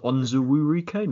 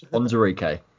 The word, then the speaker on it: Onzurike: When I first watched it, I was like, is it Onzuwurike? No, it's Onzurike.